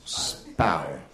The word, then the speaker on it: Spui: S P U I is pronounced spy. – How? – Spow.